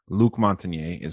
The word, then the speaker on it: Luc Montagnier: One being Luc Montagnier, I think is how you pronounce his name. If you want to look him up, L-U-C is his first name. Last name, M-O-N-T-A-G-N-I-E-R. Luc Montagnier is how I would pronounce it.